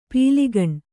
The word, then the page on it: ಪೀಲಿಗಣ್: pīligaṇ